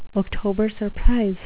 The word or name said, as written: october surprise